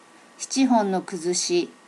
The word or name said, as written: schichi hon no kuzushi